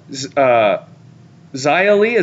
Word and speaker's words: Xia Li: She eliminated Naomi, Beth, Toni Storm, Xia Li, is that how you say it? – Yeah.